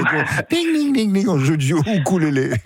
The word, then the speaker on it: ukulélé: Alors, normalement, on dit ukulélé. Le U en océanien se prononce ou.